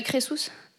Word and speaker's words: Crésus: Oui, oui, c'est pas Crésus, hein, c'est Crésus. Oui. Oui. Comment ça s'écrit ? – C-R-E-S-U-S.